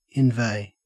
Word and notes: inveigh: Australian English

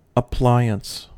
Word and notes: appliance: American English

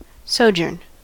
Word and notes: sojourn: American English

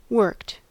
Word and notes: worked: American English

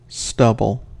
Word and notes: stubble: American English

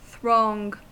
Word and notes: throng: American English

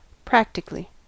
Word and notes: practically: American English

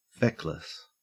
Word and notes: feckless: Australian English

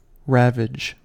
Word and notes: ravage: American English

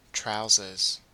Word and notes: trousers: Australian English

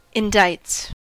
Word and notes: indicts: American English

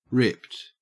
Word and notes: ripped: Australian English

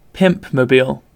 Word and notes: pimpmobile: British English

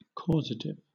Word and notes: causative: British English

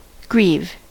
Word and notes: grieve: American English

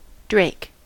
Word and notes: drake: American English

